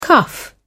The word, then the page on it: cuff: British English